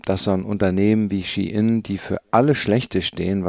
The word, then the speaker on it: Shein: Äh, Schein oder Nichtschein. Ähm, äh, das ist natürlich nicht korrekt ausgesprochen, weil der Konzern, äh, über den es geht, um den es geht, äh, wird eher ausgesprochen Shein.